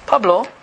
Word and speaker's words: Pablo: P-A-B-L-O, how do you pronounce that? – Pablo.